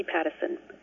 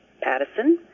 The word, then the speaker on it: Pattison: It is D-A-R-C-Y for the first name Darcy and Pattison, P-A-T-T-I-S-O-N, not Patterson, P-A-T-E-R-S-O-N. It has an I, not an E-R in the middle.